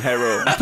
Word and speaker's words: Herro: How do you say his name? Tyler Herro. – Herro. Put some respect on his name.